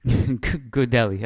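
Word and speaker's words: Gudelj: I think you could see, how do you say his name again one more time? Gudelje? – Gudelje, according to the Liga TV broadcast, yeah.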